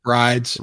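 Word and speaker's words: Wridz: And then the last one we've heard about, too, is that, how do you pronounce that one? – Rides. – Rides with a silent W, which is stupid as hell.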